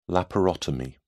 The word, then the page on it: laparotomy: British English